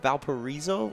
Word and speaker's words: Valparaiso: Some of these guys that have Division I offers, like Trey Hayes, who did just get another Division I offer to Valparaiso.